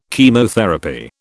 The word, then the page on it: Chemotherapy: British English